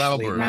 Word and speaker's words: Lauber: Hernan Lauber. Am I saying it right, Hernan? – It's Lauber, actually. – Lauber, okay. You Americanized it.